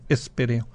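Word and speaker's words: Héspero: Acento en la Espéreo.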